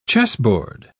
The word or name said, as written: chessboard